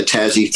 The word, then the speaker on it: Tazzy: Am I saying that right? It's Tazzy? – Yeah, T-A-Z-Z-Y, Tazzy Fund.